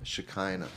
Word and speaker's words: Shekinah: Rhymes with vagina? – Shekinah, okay.